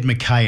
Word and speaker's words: Mackay: I'm glad you said Mackay and not Mackay, because that is the correct pronunciation. My mum was a North Queenslander and she always used to say, you can tell somebody who's from Mackay because that's how they say it.